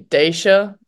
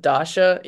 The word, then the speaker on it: Dasha: they say Daisha instead of Dasha, even if they spell it correctly.